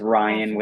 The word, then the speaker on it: Ryan: And it's Ryan with an R, R-Y-A-N.